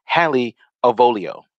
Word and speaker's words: Hallie Avolio: Hallie Avolio.